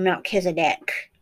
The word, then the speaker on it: Melchizedek: So you get the Malachi to Zeki, or Malacha to Zeki, or Melchizedek, as some people pronounce it in English.